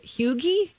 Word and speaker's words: hygge: Hygge? – Hygge. – Hygge. – Or no. Huga? I think it's actually hygge. Yeah. Not Hugh like Hugh Jackman. Who like the who. – H-Y-G-G-E.